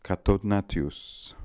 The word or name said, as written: Cathodnatious